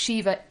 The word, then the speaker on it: Shiva: For those who are confused by Bruno's pronunciation, it's Shiva Ifrit and Leviathan.